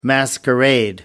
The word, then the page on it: masquerade: American English